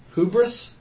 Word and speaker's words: hubris: It is a form of humility, lest in our hubris. So I needed the H is silent.